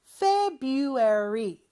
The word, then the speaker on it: February: 물론 February라고 철자는 되어 있지만 그 R 발음, 첫 번째 R 발음은 하지 않는다고 했어요. February 이렇게 발음하면 되고요.